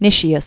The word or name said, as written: Nicias